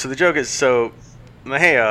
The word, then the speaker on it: Mexia: And one of the guys asks the others, "Hey, how do you say that name?" Uh, it's like, "I think it's Mejia." It's like, "No, no, look at it. It must be Mexia."